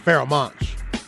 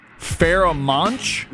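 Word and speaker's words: Pharoahe Monch: I don't know if it's a Dre beat, but the artist Farrah Monch. – Farrah Monch? – Yeah. – You spell that for me? What is that? – P-H-A-R-O-A-C-H-E, Monch, M-O-N-C-H. – Farrah Monch.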